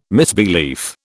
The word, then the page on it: misbelief: British English